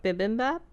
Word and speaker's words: bibimbap: Is it Mimi Bap? Mini Bap? – Bibimbap? – Bibimbap. I don't know where the heck the Mimi came from.